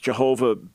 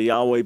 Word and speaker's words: Yahweh: Yahweh is the personal name of God, pronounced in centuries past Jehovah, based on what I would say is a misunderstanding of the Hebrew. Most scholars today would say Yahweh,